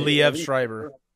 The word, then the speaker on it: Liev Schreiber: Liev Schreiber.